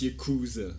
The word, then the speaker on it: Yakuza: It's Yakuza.